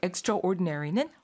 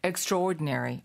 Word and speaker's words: extraordinary: 근데 그 단어 자체가 스펠링은 extra하고 ordinary인데 발음이 좀 특이해요. Extraordinary. – Extraordinary. 맞다. – 원래 그 천천히 발음하면 extraordinary. – 네. – But normal speed, extraordinary.